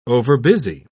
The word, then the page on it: overbusy: British English